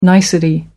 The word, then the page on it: nicety: American English